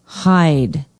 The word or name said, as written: hyde